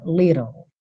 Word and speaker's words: little: Então, little, a gente tem esses dois T's soando como rarara, little, little. Depois do rarara, você leva a língua para o céu da boca para fazer o L. E esse F final não é pronunciado, tá? Então, little. Se você tivesse uma referência britânica, seria little, little.